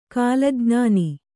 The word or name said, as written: ಕೀಲಜ್ಞಾನ